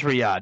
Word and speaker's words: Riyadh: I'm an idiot. It is Riyadh.